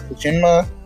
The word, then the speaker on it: Tsushima: Go for Tsushima.